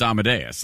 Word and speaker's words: Amadeus: Because if you've ever heard it, you know it's Amadeus. Like, it just is.